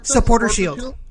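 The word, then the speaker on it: Supporters' Shield: Supporters Shield.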